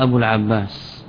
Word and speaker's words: أبو العباس: جاء أبو العباس: هل إثبات الواو كتابة يعتبر خطأ؟ لا، حذفها خطأ، نص تكتب أبو العباس ولكن تحذف في النطق، تحذف في النطق، ولذلك الإعراب يتبع الملفوظات للمرسومات.